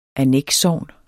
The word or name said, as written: annekssogn